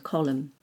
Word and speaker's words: column: But if it ends with MN, the N is silent, like in autumn and column.